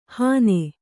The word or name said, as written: ಹನೆ